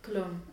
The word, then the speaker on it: Köln: I don't know how you say it in English. Köln. – Cologne. – Cologne, okay. – Like the aftershave.